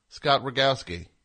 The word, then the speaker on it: Rogowski: This Monday, I'll be a part of Running Late with Rogo Scott Rogowski. Did I say his name? Yeah. Scott Rogowski.